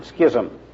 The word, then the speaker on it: schism: has a book called Schism and Renewal, or as some people pronounce it, Schism.